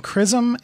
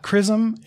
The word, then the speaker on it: XRISM: Please believe me, it's pronounced CRISM. The acronym is X-R-I-S-M, and it's either CRISM or CRISM, and that is not what it is, right? I know it starts with X-ray and should not be pronounced K, but that's what we're going with.